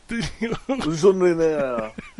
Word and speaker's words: Jean Reno: Did you really call him John Reno? Is that John Reno? – John Reno. – You are so anti. – Jean Reno. – Jean Reno. – Yeah, you got to say it right.